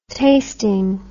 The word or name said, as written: tasting